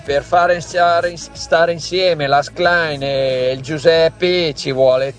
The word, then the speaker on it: Schlein: cioè non si può sentire, caro ascoltatore, Schlein, non è difficilissimo, anche se sì, non sappiamo il tedesco.